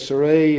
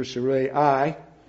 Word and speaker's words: Sarai: And Abram took Sarai, that I'm trying to pronounce that the way it's indicated, but nevertheless, Sarai, and the Bible has it here, Sarai,